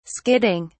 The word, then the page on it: skidding: British English